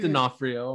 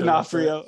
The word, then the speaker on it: D'Onofrio: I never know how to say his name. Was it Vincent D'Onofrio? – I go with D'Onofrio. I'm pretty sure. – D'Onofrio.